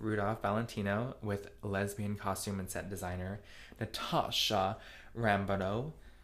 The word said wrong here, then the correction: Rambova: Sorry, that was mispronounced. Natasha Rambova.